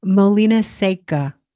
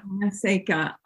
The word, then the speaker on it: Molina Seca: Molina Seca. It's Molina. A dry mill. Molina Seca. – Molina Seca.